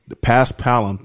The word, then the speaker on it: Paspalum: I think it's called Paspalum. I don't know if I said that right. We've been butchering. Paspalum. Thank you, because we've been butchering shit on this show for a couple weeks now.